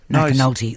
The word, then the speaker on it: McNulty: We will speak to, among many others, the Emergency Management Minister, Kieran McNulty. McNulty, excuse me, I always miss out that syllable. I'll have to practice.